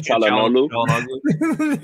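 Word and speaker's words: Çalhanoğlu: What about am I pronouncing it wrong? Chagamolo. – Chalanoglu. – There we go.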